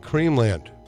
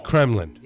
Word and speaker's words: Kremlin: Oh, okay, you went Creamland. – Yes, because that's the way I spell it. – I know. It's Kremlin. – Yeah, it's wrong. – It is Kremlin.